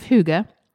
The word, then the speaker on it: Hygge: We think that's how you say it. We looked it up. – We did just watch a YouTube video. – I think we're saying it correctly, but it is spelled H-Y-G-G-E.